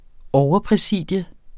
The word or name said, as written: overpræsidium